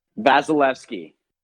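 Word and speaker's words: Vasilevskiy: Vasilevsky.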